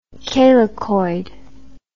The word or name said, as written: calycoid